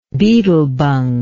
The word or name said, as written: beetlebung